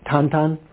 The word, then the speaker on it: Tintin: Is that pronounced right? Tintin? Yeah.